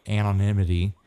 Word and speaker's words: anonymity: There's, you know, the anonymity. Did I say it right? Anonymity? – Yep, there you go.